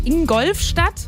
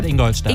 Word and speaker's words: Ingolstadt: Yannick zum Beispiel aus Bayersbronn, der sagt Ingolfstadt. – Statt Ingolstadt. – Ingolstadt.